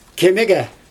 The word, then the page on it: kemigE: Xyzyl